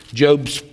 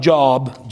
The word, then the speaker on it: Job: Job 14, it looks like Job, J-O-B, pronounced Job.